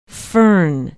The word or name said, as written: Fern